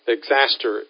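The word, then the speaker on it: Xastir: Exaster, X, let me tell you exactly how it's spelled, because sometimes I X-A-S-T-I-R. X-A-S-T-I-R is the name of it.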